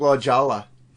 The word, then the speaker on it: La Jolla: We were friends here in Fort Wayne years ago, like mid-90s, and she moved to La Jolla, which is, I believe, south of San Diego. – Yeah. When you see it, it looks like La Jolla. Because the J is silent.